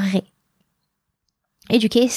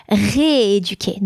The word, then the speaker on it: rééduquer: Re or ré? Éduquer starts with again the sound a, which is a vowel sound. Rééduquer, not rééduquer. Rééduquer.